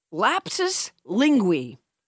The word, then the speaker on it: lapsus linguae: This week's word is, let me make sure I'm saying it correctly, lapsus linguae.